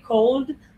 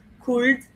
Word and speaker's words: could: We don't pronounce the L. We don't say cold, could, we say could.